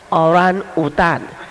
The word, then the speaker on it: orangutan: Sean 到底是不是从 Oran Udan 进化过来的？那我们在美国读作 Orangutan 哈、啊，我们是读错，你晓得吗？啊，是谁从山来？你从山来看的？不是，昨天晚上有一位从三大甘南在那里，是读作 orangutan 对不对？啊，这个他们的真正的字，他就说 man of the forest， oran 是人啊。